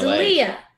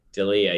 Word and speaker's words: D'Elia: D'Elia. – D'Elia, yeah, whatever. – We always get his name wrong. – Yeah. – D'Elia.